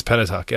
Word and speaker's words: Pentateuch: A Pentateuch. – Pentateuch is the first five books of the Bible. – Pentateuch. Did I pronounce it wrong? – It's Pentateuch.